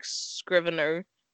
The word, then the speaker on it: Scrivener: I really like Scrivener, and I'm glad that's how you say it because that's how I've been saying it in my head.